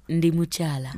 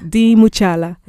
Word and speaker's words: Ndimuchala: Ndimuchala. – Ndimuchala. – Yes. – Okay. – So Ndimuchala is a Luganda statement that says I am. Ndi means I am.